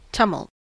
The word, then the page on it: tumult: American English